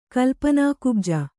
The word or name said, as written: ಕಲ್ಪನಾಕುಬ್ಜ